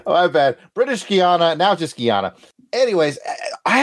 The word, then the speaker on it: Guiana: At the time, it was British Guianan. Am I it? It's spelled differently, so I imagine no, it's slightly it's pronounced the same.